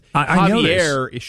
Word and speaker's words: Javier: You said Xavier, but that's okay. I do that all the time because Javier should be a J to me.